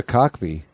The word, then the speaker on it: Kotb: Her last name is spelled K-O-T-B. Kid on the block. – K-O-T-B? – K-O-T-B, Kotb. – That can't be right. – It is Kotb. – Is that how you say it, though? – I think so. Yep, Kotb.